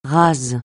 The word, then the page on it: ras: francais